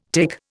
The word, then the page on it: tic: Indian